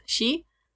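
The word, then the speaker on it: shi: because it can be pronounced the same way as the word for death, she.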